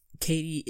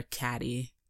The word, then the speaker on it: Cady: But yes, so after that, we get into actual class where Katie is having a difficult time. She gets in to homeroom. She's introduced. They get her name wrong. They call her Caddy because it's spelled C-A-D-Y.